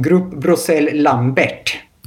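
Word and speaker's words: Groupe Bruxelles Lambert: Man brukar uttala det som Grupp Brossel Lambert och de äger bland annat i Adidas.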